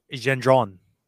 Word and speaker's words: Gendron: In French, they say Gendron.